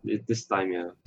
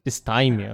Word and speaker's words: dysthymia: Dysthymia. – Dysthymia, okay.